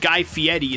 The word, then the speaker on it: Guy Fieri: But Guy Fieri is how it's pronounced.